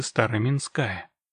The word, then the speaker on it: Староминская: Община Топтыги облюбовала северную окраину станицы Староминская. С ударением на последний слог, понятное дело.